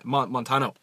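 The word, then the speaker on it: Montaño: So, Nico Montana. Is that how you say her name? – Montano.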